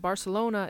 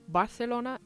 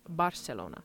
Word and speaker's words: Barcelona: I should also mention that Barcelona is pronounced both Barcelona and Barcelona. So in most of Spain, it's Barcelona, but in Catalan, which is one of the main languages of that region, it is Barcelona.